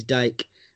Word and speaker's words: Dyche: How do you say his name? Is it Dyche or Dyche? I mean, what's the right the correct analogy? – I think here in the Dogcast, I think he's Dyche.